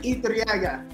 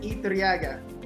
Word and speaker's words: Iturriaga: Name is Jasper Iturriaga. – Say it again. – You pronounce it Iturriaga. Iturriaga. Yeah.